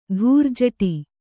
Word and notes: ಧೂರ್ಜಟಿ: Kannada

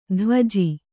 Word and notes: ದ್ವಿಜ: dvija

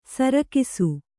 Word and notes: ಸರಕ್ಷ: sarakṣa

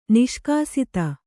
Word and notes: ನಿಷ್ಕಾಸಿತ: Kannada; niṣkāsita

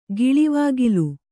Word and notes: ಗಿಳಿವಾಗಿಲು: giḷivāgilu; dial.

